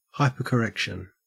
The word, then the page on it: hypercorrection: Australian English